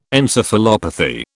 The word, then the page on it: encephalopathy: British English